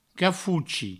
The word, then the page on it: cafochi: cafouchi